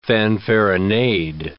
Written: fanfaronade

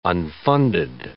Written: unfunded

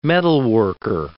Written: metalworker

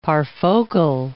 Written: parfocal

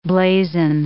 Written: blazon

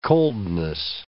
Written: coldness

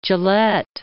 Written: Gillette

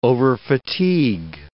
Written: overfatigue

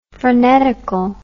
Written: frenetical

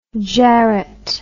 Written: jarret

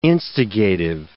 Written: instigative

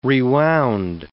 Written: rewound

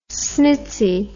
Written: snitzy

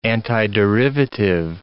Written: antiderivative

